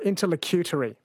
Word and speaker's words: interlocutory: It's pronounced interlocutory. Sorry, interlocutory. That is correct, yes. Susie, it's a proceeding that is ancillary to the main case being heard, interlocutory.